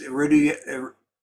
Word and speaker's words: erudition: This was not an idle exhibition of literary, I don't know how to pronounce this. – Erudition. – Erudition. Is that right? – Close enough.